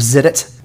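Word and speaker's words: Xidit: It's called Lords of Zidit. Zidit is spelled with an X. X-I-D-I-T.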